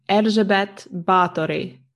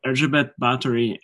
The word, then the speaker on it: Erzsébet Báthory: I'm going to ask you for the correct pronunciation. – I think my pronunciation is pretty correct, so it would be Erzsébet Báthory. – Okay, yeah. Erzsébet Báthory, who is a sort of legendary figure and one who has appeared in quite a bit of popular culture.